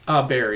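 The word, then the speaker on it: Baer: And also, if people message you privately, Hug Your Haters, how do you say his last name? Jay Bauer? Bayer? – Bayer, yeah, Jay Bayer.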